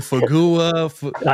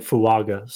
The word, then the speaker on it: Fuaga: Fagua. – I know. Like, so it's Taliese and then Fuaga.